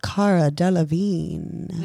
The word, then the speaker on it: Cara Delevingne: so today we are looking at the birth chart of Cara Delevingne.